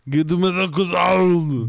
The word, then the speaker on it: croissant: Or it's like Americans eating croissants. – You mean a croissant? – I guess you could pronounce it like that if you're dumb.